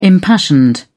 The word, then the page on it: impassioned: British English